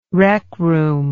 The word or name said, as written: rec room